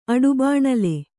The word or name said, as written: ಅಡುಬಾಣಲೆ